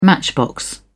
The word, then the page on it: matchbox: British English